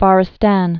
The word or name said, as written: Farsistan